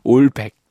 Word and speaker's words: 올백: 이 올백이 약간 머리 넘긴 것처럼 올백. 네, 네. 발음 정정합니다. 올백 마주왔을 때요. 올백.